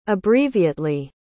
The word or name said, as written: abbreviately